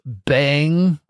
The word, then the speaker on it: Bem: By the way, Bem means good in Portuguese. Also in Portuguese, it's pronounced bang, which is a lot more fun to say.